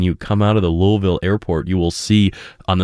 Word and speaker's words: Louisville: That's right, Louisville. Not Louisville, not Louisville, it's Louisville. Two syllables.